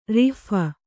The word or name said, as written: ರೋಫು